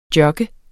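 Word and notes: jogge: Danish

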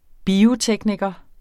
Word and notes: biotekniker: [ ˈbiːoˌtεgnigʌ ]; [ bioˈtεgnigʌ ]

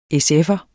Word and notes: SF'er: [ εsˈεfʌ ]; [ ˈεsˈεfʌ ]

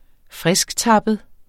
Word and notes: frisktappet: [ ˈfʁεsgˌtɑbəð ]

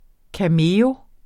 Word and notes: cameo: kaˈmeːo; ˈkameˌɔw

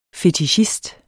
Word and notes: fetichist: fetiˈɕisd

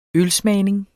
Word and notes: ølsmagning: -ˌsmæːjneŋ; -ˌsmæˀjneŋ

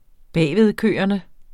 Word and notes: bagvedkørende: ˈbæjˀveðˌkøˀʌnə; -ˌkøːʌnə

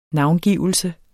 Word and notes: navngivelse: ˈnɑwnˌgiˀvəlsə

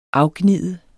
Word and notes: afgnide: -ˌgniˀðə

